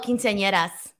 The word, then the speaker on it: quinceañeras: Quinceaneras. – Oh, quinceañeras.